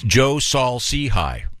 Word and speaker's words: Joe Saul-Sehy: Our guest has a very complicated name to pronounce. It's Joe Saul Sehy. Did I get it right? – Nice job.